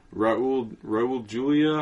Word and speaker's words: Raul Julia: It stars Angelica Houston, Raul Julia, I feel like I'm pronouncing that wrong. – You got it.